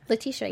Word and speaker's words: Letitia: Letitia, yeah.